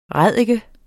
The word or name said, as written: ræddike